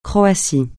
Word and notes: Croatie: francais

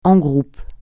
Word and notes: en groupe: français

